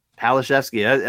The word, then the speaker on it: Palaszewski: Palaszewski. I mean, P-A-L-A-S-Z-E-W-S-K-I. It's phonetic, obviously.